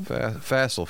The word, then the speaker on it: facile: Facile. F-A-C-I-L-E. – Wow. That goes up to the top 10 board. A fancy pants word. – Fancy pants. It's only two syllables.